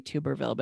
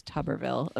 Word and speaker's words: Tuberville: which I always want to say Tuberville, but it's Tuberville of Alabama.